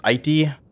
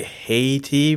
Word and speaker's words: Haiti: Americans say Haiti, but they say a lot of things wrong. It's pronounced Haiti. Easiest way to think about it is an I and a T, like it, the word it, I-T.